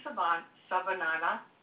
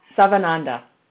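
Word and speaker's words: Sivananda: So I wanted to kind of slim it down a bit into, there's Swami Savananda. I'm saying that wrong. – Savananda. – I knew I messed it up.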